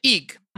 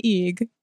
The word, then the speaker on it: egg: I pronounce it eeg. – Oh, wait, did she turn into an egg? I don't remember what happened. – She turned into an eeg. – An eeg. That's how you pronounce it where I was born. – Where was that? – Not relevant.